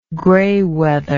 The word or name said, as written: graywether